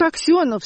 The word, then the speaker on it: Аксенов: «Тут Аксенов, смотри, Борис!» Уже по-американски с ударением на «о».